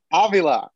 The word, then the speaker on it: Avila: Avila. Alex Avila. – Avila. – The accent is on the first A.